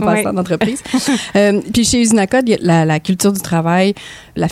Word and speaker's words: Uzinakod: Usinacode, je tiens à le spécifier, en fait, ça s'écrit U-Z-I-N-A-K-O-D.